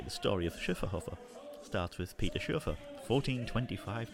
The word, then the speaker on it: Schöfferhofer: Let me get the pronunciation right. Schöfferhofer. There's a little umlaut on the first O.